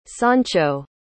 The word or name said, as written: sancho